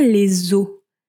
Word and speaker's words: eaux: Be careful, it's pronounced exactly the same way than bones. In French, if you want to say bones, it's also des eaux, but it's written O-S. And waters is written E-A-U-X.